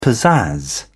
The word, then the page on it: pizzazz: British English